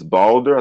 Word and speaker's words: Balder: Her son's name is, I think it's Balder. I think that's how you pronounce it. B A L yeah, B A L D D R D E R, yeah, something like that.